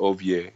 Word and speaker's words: Ovie: The name is Ovie, but it's pronounced Ovie in my parents' language, O-V-I-E.